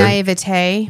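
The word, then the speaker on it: naïveté: I don't know if it was naivety. Mm-hmm. Is that the right pronunciation of that word? – Naivete. – Naivete.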